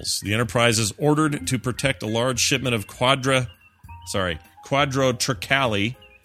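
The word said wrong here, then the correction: quadrotriticale: Quadra, how would you say that? – Quadro triticale. – No. What? No. Quadro triticale. – Oh, quadro triticale, which is quadro a wheat rye hybrid.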